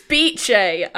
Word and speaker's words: Bice: It's spelled B-I-C-E. – Yeah, but whenever anyone pronounces it, I swear it's bitch. – It's Beache, I think. – Beache.